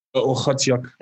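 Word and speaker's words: Okhotiuk: How do you pronounce Nikita's last name? Because I think we've gone through this with many people. Do you know the official pronunciation? – It's Ochotiuk. – There we go. Now we have it.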